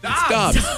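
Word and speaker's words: Doubs: Romeo Dubs? Dobbs? Dubs? I know I was going to get that wrong. I read that and I said, oh, what is this? – I should have phonetically spelled it for you. Sorry. – It's Dubs? – Dobbs. – Dobbs.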